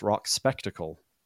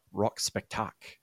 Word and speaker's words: Rock Spectacle: what I would have at the time pronounced Rock Spectacle, which I've since learned is French-Canadian, Rock Spectacle.